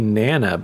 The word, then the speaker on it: Nonna: I think that's supposed to be Nana, but it's spelled N-O-N-N-A, Nutella Nana.